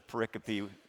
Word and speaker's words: pericope: It's called, the word is think from the Latin, pericope, pericope, P-E-R-I-C-O-P-E.